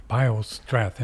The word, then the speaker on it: Biostrath: Bio, B-I-O, hyphen, and then S-T-R-A-T-H, Strath. – Strath, Biostrath.